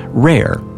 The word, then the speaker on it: WRAIR: Today's interview with Dr. Weerts will focus on her team's research at Walter Reed Army Institute of Research, also known as WRAIR, pronounced RARE,